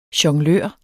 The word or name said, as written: jonglør